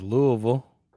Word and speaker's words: Louisville: Oh, the only correct way to pronounce it, which is Louisville.